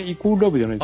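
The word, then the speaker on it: =LOVE: 読み方が違うはずなんですけどね。 – え、イコールラブじゃないですか。あ、そうなんですか、イコール。 – かっこイコールラブって書いてあるから。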